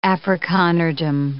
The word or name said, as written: Afrikanerdom